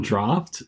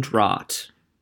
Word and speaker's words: Draught: Is Carlton Draft? – Correct. So that's the beer. – Correct pronunciation. – Yes. Now, if I pronounce it, well, I was... for a while, Carlton Draught.